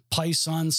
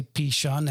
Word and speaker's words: Pishon: The first name of the name of the first is Pison, some people say, Pishon, some say Pison. I'm going to say Pishon. That's how it's pronounced in the Hebrew.